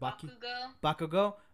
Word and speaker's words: Bakugo: Like I think for My Hero Academia, Deku and Bakuga, I can never say his name right. What was it? – Bakugo? – Like from them, Kachan?